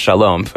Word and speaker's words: Shalom: Yes, I know the Hebrew word is pronounced Shalom, but in Chicago, you call it Sholom if you know anything about Chicago.